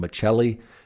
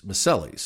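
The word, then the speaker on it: Miceli: And, you know, before we had that conversation, I asked her because I'd heard other people pronounce her name Maselli. And she said, well, that's how they pronounce it in Italy. Here it's Maselli.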